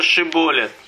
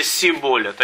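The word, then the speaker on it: Шиболет (שיבולת): Он говорил «нет», то говорили они ему: «Скажи-ка Шиболет». Они говорили «Сиболет». Они ему говорили «Ши», они говорили «С». Не мог правильно выговаривать, то они хватали его и резали его у переправ Ярденских.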